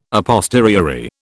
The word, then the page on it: a posteriori: British English